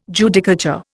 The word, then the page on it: Judicature: Indian